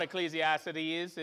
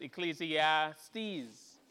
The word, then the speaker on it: Ecclesiastes: It's not Ecclesiastes, it's Ecclesiastes, apparently. Yeah, the syllable, I keep messing it up.